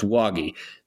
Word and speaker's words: Wagi: And now I know his, like, with certainty, his last name is pronounced Wagi.